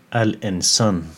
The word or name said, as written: Al-Insan